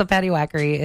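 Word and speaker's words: Paddywhackery: Oh, I've been saying it wrong? – No. Okay, so Patty Whackery is a term for sort of the stereotypical depictions of Irish culture.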